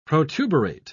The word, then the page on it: protuberate: British English